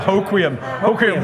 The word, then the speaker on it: Hoquiam: Yeah, Hoquiam.